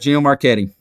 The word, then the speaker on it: Gino Marchetti: So Gino Marchetti, if I pronounced that. – Yep, right. Gino Marchetti.